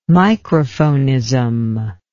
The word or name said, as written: microphonism